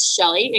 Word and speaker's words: Chelle: And my name is spelled a little bit weird. It's Chelle. People are like, how do you pronounce that? It's Shelley, again, Chelle Johnson.